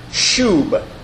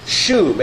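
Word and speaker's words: shub (שׁוּב): Now, the word translated returned in our text translates the Hebrew word shub, S-H-O-O-B, I guess would be the way you might want to spell it in English, shub.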